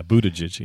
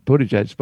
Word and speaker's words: Buttigieg: Yeah, Buttigieg. Yep. – Buttigieg.